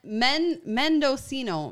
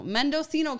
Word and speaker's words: Mendocino: And it's an earthy, creamy, grapes grown to, Medocino? – Medocino. I don't know. – Wait, how do you say that name? Medicinal? – Medicinal? That's not it. Which one are you on? Okay. Mendocino. Mendocino County, California.